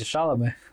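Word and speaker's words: Шаламе: Ну, Википедия говорит, что вы оба не угадали на первый слог вообще. Тимати Шаломе. Шаломе.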